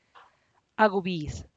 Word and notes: a gubiz: Teotitlán del Valle Zapotec

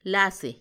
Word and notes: lasi: Macuiltianguis

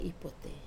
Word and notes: pote: wamesa

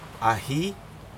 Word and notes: ahii: Aneityum

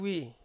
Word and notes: wi: Ansus

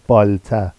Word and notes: bɑlta: Vafsi

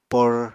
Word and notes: por: Apatani

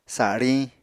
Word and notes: sariñ: apatani

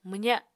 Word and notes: mwza: Apatani

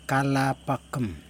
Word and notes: kalapakim: Yokoim

